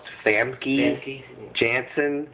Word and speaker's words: Famke Janssen: I believe her name is pronounced Famke Janssen.